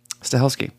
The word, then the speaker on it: Stahelski: The screenplay will be developed for Chad Stahelski... Fuck, now I said his name wrong. Stahelski.